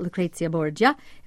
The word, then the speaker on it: Lucrezia Borgia: And let's tell people a little bit about Lucrezia Borgia. Am I saying that correctly? – Yes.